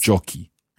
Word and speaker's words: giochi: C-H-I. – So the ending C-H-I, we put an H in order for the C sound to stay hard. – Yes. – Giochi.